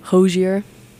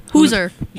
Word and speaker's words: Hozier: Hozier. – Hoosier. – That's how he says it, is Hozier. – Hozier. I agree with that. – Hozier. – Yeah. Okay. – Yeah. Hozier.